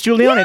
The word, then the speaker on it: Jewelyana: Oh, it's Juliana. – Yeah, that's, well, I like the way she spelled that. – Tell everyone how it's spelled. It's spelled like Jewel. – J-E-W-E-L-Y-A-N-A.